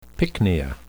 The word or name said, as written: pycnia